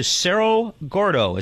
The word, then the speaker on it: Cerro Gordo: Cerro Gordo. Am I pronouncing it right? – That is correct.